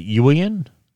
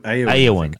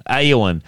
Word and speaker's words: Eowyn: Now, Miranda Otto, Australia's Miranda Otto, will reprise the role of, how do you say this, Richard? Is it Ewan? Ewan? – Eowyn. – Eowyn.